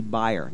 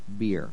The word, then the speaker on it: bier: Then he came up and he touched the bier, and yes, that is the correct way to pronounce it. I looked it up because it sounded weird to me. I thought maybe it's supposed to be buyer. No, it's pronounced beer.